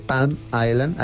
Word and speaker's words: Palm Island: Entendido que tiene una casa en Miami, en la parte de Palm Island. Ayúdame con la pronunciación. – Palm Island. – Se dice Island, pero se dice Palm Island.